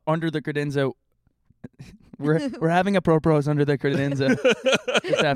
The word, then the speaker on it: apropos: Did I say that right? – Yeah. Apropro. – Apropos. – Yes.